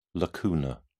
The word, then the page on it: lacunar: British English